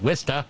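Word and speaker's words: Worcester: A few years back, I learned how to pronounce that, Wista.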